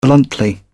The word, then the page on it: bluntly: British English